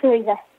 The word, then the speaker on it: Talitha: It says you have four older brothers, Justin, Alec, Noah, and Judah Bateman, and three older sisters, Hannah, Leah, and is it Talitha? Is that how you say her name? – Yes, it's Talitha.